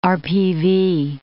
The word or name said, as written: RPV